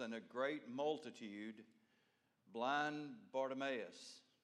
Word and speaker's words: Bartimaeus: Now, some of you have heard that pronounced other ways. This is the way I pronounce it. Okay? And if I tried to pronounce it any other way, I would say it four different ways tonight. So I'm going to stick with what I learned today as Bartimaeus, the son of Timaeus, sat by the road begging.